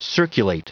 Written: circulate

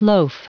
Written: loaf

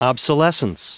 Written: obsolescence

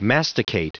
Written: masticate

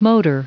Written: motor

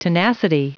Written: tenacity

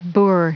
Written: boor

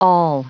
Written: awl

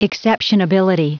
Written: exceptionability

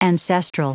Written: ancestral

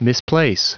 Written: misplace

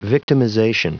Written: victimization

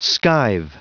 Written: skive

